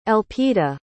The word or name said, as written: Elpida